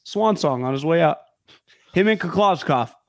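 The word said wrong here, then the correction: Kliavkoff: How do you say his last name? – Who's George? Last name? Oh, Kliavkov. – Kliavkov. – Yeah, Pac-12 commission.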